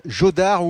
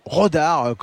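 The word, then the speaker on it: Jódar: Et puis l'attraction, la nouvelle star du tennis, le jeune Raphaël Jodard, ou Rodard comme vous voulez, si vous le prononcez à l'espagnol ou à la française,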